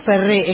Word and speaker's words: Ferré: Digo Ferré, es con acento en la E, ¿está bien? Ah, bien.